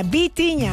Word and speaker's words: Vitinha: No Bocinha, Vitinha.